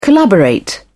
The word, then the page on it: collaborate: British English